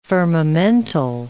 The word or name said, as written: firmamental